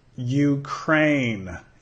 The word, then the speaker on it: Ukraine: Ukraine. Empieza con sonido Y, Ukraine.